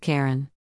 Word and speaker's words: cairn: It is a tattoo of a rock cairn. Yes, it's pronounced Karen.